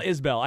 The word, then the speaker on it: Isbell: He called him Kyle Isabel like three times in the first segment, and I didn't want to tell you you're adding a syllable. – It's so. – Kyle Isbell. I get it.